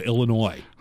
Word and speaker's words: Illinois: The S is silent on the end of Illinois.